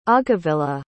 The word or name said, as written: aghavilla